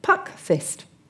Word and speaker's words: puckfyst: And then we have, I'll say this carefully, puck fist. – Puck fist? – Yes, only the fist is spelt F-Y-S-T. – It's a real word. – Yes. – P-U-C-K-F-Y-S-T.